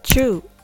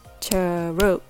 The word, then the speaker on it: true: It only has one syllable or one beat true. So it means you need to say this word in one breath. You don't want to say it in two breaths like churu.